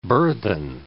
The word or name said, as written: burthen